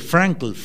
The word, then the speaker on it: Frankl: La cosa es que había el escritor Víctor Frank, de él se tomaron muchas experiencias. Frankl se pronuncia, él dice: quien tiene una razón para vivir es capaz de soportar prácticamente cualquier cosa.